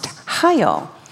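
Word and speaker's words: Hayil (חיל): And the word valor, actually the Hebrew word in the Old Testament for valor is spelled C-H-A-Y-I-L, and it's pronounced Hayil.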